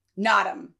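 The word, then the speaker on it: Naadam: All right, so this is a brown ribbed Nedam. I believe I'm pronouncing that right. I actually wanna look it up. You see, I try. Nadam. So I didn't pronounce it right.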